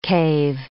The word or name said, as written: cave